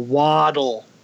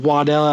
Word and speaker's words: Waddell: Waddle. Waddell. How do you get Waddell out of that? That's Waddle. – It's Waddell, bro. That's how you. that's gonna, how it's pronounced.